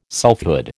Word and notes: selfhood: American English

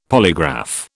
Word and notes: polygraph: British English

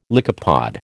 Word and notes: lycopod: American English

